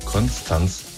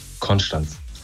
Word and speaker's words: Konstanz: Viele sagen immer Konstanz, es heißt aber Konstanz. Einfach nur Konstanz.